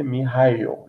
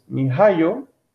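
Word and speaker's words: miHoYo: Esto de acuerdo con la propia desarrolladora china, eh, me parece que se dice Mihayo, lo estuve, lo estuve investigando y parece que esa es la pronunciación.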